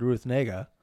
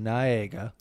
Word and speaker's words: Ruth Negga: Well, the thing was, I originally said what you said, Ruth Negga.